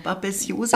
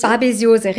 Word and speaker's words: Babesiose: In diesen Reiseländern, da existiert auch die Babesiose, die kommt von den Zecken, ne? – Ja, genau. – Richtig ausgesprochen, Babesiose. – Babesiose, richtig. Ja, ganz genau.